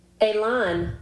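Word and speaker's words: élan: Elon. Elon. – Elon? – Elon. And it's spelled E with a little apostrophe on it. – Mm-hmm. – L-A-N. It's French.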